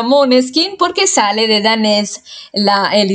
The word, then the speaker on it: Måneskin: Y bueno, digo Måneskin porque esa es la pronunciación correcta.